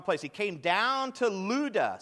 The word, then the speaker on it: Lydda: It says, he came down also to the saints who lived in, that's pronounced Lydda. Like ludicrous. No, not really.